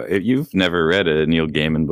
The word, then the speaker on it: Gaiman: And his name is pronounced that way, by the way. I looked it up just to make sure, and that's how he says his name. So it's not Gaiman.